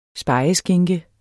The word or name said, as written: spegeskinke